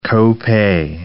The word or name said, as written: co-pay